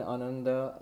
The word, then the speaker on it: Ananda: And in Ananda, it is Ananda.